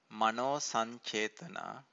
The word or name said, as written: mano-sancetana